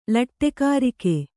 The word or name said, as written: ಲಟ್ಟೆಕಾರಿಕೆ